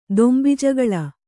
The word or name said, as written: ದೊಂಬಿಜಗಳ